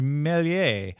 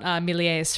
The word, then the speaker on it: Méliès: Gunn, Georges Méliès, Jean Chanher. How do you say it? – I think you actually say the S in Méliès. Yeah, I believe it's like that.